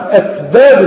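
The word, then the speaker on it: أسباب: ومعظمنا بينطق السين غلط، حتى فكر انها بنطقها صح، لكن معظمنا ينطق السين زاد وهو لا يجري. اين لكن هي ايه؟ اسباب. اسباب. لكن معظمنا 95% كده. اي نعم، ينطق السين زاد وهو يظن انه ينطقها فيه، والامر ليس كذلك.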